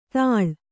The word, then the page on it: ತಾಳ್: Kannada